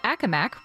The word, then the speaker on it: Accomack: Up on South 13 in the county of Accomack, which I now know is how it's pronounced, in the vicinity of Route 662, there are delays because of a tractor-trailer crash.